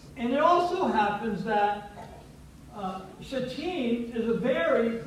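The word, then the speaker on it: Shittim: Some people don't like to say it the way phonetically we typically would say that, and so they say chittim, and they do all kinds of weird things with it, but it is shatim. And the pronunciation and the emphasis, the accent, I guess is the right way to say it from an English language, the accent is on the second syllable, shatim.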